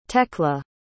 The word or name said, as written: tekla